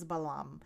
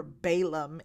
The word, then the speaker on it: Balaam: And his name is Balaam, or Balaam, if you're pronouncing it in English or familiar with it in English.